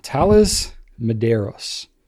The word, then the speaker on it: Thales Medeiros: Thales Medeiros.